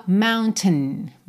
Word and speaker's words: mountain: Essa parte final que vem ali entre o T e o N, ela é muito reduzida. Aqui a gente até ouve um pouquinho de vogal, mas soa mountain, mountain. É só esse ten, ten. Existem algumas pronúncias que, na verdade, passam do T direto pro N. A gente tem mountain. Mountain, mountain. É como se você fizesse uma puxada para dentro desse som nasal, sabe?